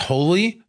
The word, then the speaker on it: Holi: It was, I believe the day this was released was the, I think it's pronounced Holi Festival in India, H-O-L-I.